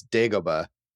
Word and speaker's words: Dagobah: I like the atmosphere, as we said, Hoth and the Sky City and Dagobah. You know, is that how you pronounce it? – So, I think it's Dagobah.